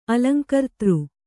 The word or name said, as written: ಅಲಂಕರ್ತೃ